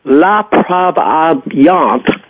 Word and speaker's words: La Provenance: Then the next race, the grade 3, La Provenance, as Bobby told me how to pronounce it, as a mile and a half at Calder.